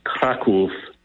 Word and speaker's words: Kraków: I am actually in Krakow, Poland, properly pronounced Kraków, Poland, and you know, we're here on humanitarian purposes.